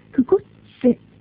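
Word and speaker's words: クコㇿ チセ: ですから、くころちせはくこっちせとなります。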